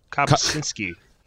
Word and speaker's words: Kopczynski: Kopczynski. – Is it cup like a cup? Kopczynski? – No, it's cop like all those people everyone hates right now.